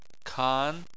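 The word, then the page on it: kan: tone 2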